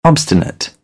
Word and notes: obstinate: British English; ɒ

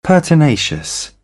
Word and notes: pertinacious: British English

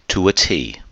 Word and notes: to a T: idiom

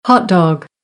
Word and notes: hotdog: American English; ɑː

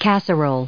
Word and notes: casserole: noun

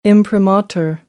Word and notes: imprimatur: American English; ɚ

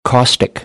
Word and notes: caustic: American English; ɑː

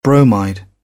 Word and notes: bromide: British English; əʊ

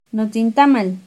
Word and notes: notzintamal: Náhuatl

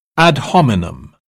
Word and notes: ad hominem: British English

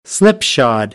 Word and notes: slipshod: American English; ɑː

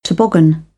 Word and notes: toboggan: British English

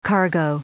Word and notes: cargo: noun

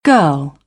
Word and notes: girl: British English; ɜː

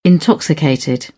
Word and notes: intoxicated: British English

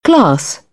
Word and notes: class: British English; ɑ

